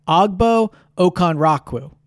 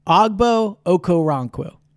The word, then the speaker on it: Ogbo Okoronkwo: The Eagles on Tuesday signed defensive end Ogbo Okoronkwo. Ogbo Okoronkwo from the Cleveland Browns.